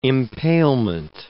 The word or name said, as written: impalement